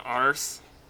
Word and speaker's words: arse: Kiss his own arse. – That is correct. Yes, and you even got the pronunciation right.